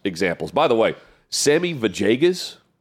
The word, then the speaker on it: Villegas: It's the same Hispanic name where the two L's are pronounced Vajay instead Vajay. Of it looks like Villegas. – Yes. – But it's Vajegas, yeah, I believe.